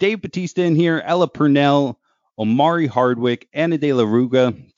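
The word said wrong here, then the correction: Reguera: Damn, I don't think I said that right. Regrugra? Damn it. I don't know. Theo Rossi. – Yeah, I think it's Regera.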